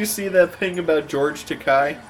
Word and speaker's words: Takei: Let's see, Takei. His name is Takei. – Takei because it rhymes with gay.